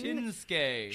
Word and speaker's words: Shinsuke: Shin as in shin. – Shinsuke. Shin Shinsuke. and then ske as ski, not but not it's ske. Not Polish. Not ska.